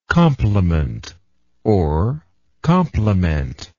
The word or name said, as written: complementer